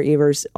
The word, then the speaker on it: Evers: I want to correct your pronunciation of our governor's name, Troy Evers. The E says its name. Troy Evers, I guess? – It is Evers.